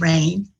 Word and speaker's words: Raine: because my name is Raine with an E on the end, mm-hmm.